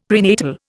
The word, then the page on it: prenatal: Indian